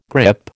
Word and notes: grip: American English

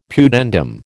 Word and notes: pudendum: American English

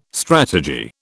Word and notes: strategy: British English

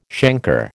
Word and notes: chancre: American English

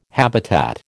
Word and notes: habitat: American English

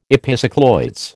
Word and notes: epicycloids: American English

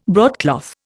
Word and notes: broadcloth: Indian

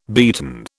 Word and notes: beaten: British English

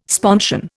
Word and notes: sponsion: Indian

